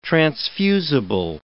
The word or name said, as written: transfusable